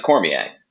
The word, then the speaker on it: Cormier: I pronounce it Cormier.